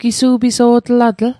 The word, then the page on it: gisu'piso'tlatl: Mi'gmaq